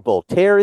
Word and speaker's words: Voltaire: And you go around, and you meet historical figures, and Voltaire is in there, and it's awesome. – You pronounced that French name very beautifully.